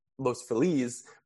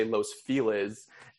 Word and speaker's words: Los Feliz: Like when I found out is, so I live in Los Feliz, which is pronounced Los, which is spelled and like said like Los Feliz, but in LA you say Los Feliz, and it's like how, and it's like literally like when I first moved here, I was like, oh yeah, I'm in Los Feliz.